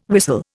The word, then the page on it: whistle: Indian